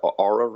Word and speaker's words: Oura: I think the Oura ring is that how you pronounce it? – Yeah. I think that one has been validated.